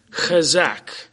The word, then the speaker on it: chazaq: Now the word encouraged here, chazak, is my impeccable Hebrew pronunciation of that word, C-H-A-Z-A-Q.